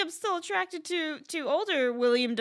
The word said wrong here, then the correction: Willem: Dafoe as well. – Willem. – Is, fuck. Oh yeah, it is Willem. It's spelled W-I-L-L-E-M.